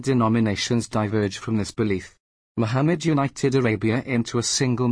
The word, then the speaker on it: Muhammad: Muhammad, Arabic, pronounced Muhammad, circa 570 CE-8th of June 632 CE, was an Arab religious, social, and political leader and the founder of Islam.